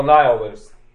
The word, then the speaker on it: nihilist: We shall then unleash the nihilist. No, nihilist. The word you're probably hearing in there is annihilate, but it's spelled like that.